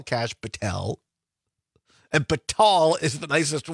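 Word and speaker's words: Kash Patel: Oh, Donna says it's pronounced Cash Patel.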